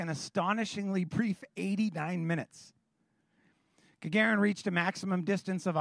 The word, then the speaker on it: Gagarin: On April 12th in 1961, a Soviet cosmonaut by the name of Yuri Gagarin, I'm sure I'm saying that wrong. Do you know? Gagarin? Gagarin, thank you.